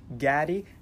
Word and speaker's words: Gatti: His name is Mike Gatti. That's G-A-T-T-I, just to make sure that nobody, you know, gets the pronunciation of the name confused.